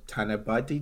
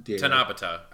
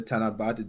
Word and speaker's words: Tanabata: Like particularly the Endless Eight and the Tanabate Day, how do you pronounce that? – Tanabata, I think.